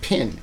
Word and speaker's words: pen: So Vicky's thing is the pen, which she says rhymes with pin. Did you ever notice that? That's the Midwestern thing.